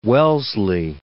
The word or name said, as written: Wellesley